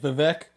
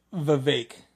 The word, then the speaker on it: Vivek: When I understand his name looks like Vivek, but it's pronounced Vivek.